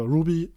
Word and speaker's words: RWBY: Wird aber Ruby ausgesprochen. Was lustig ist, weil die Hauptcharakterin heißt Ruby.